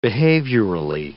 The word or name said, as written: behaviorally